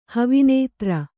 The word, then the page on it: ಹವಿನೇತ್ರ: Kannada